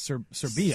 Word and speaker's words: Serbia: And I said it's actually not Serbia, it's Serbia.